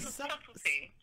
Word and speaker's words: subtlety: Am I saying that wrong? Wait, what is that? – Subtlety. Did I spell it wrong? – Subtlety. All right. So, all right. No. Now you're not subtlety. – Subtlety.